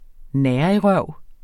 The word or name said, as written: nærigrøv